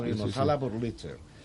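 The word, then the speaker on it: Wurlitzer: Pero sí, o sea, justo ahora venimos a hacer la Burlitzer, creo que se pronuncia Burlitzer. Sí, Burlitzer.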